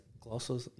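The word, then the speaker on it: Klose: Jack, fuck, how you pronounce it? Gloss? – Yeah, that's it.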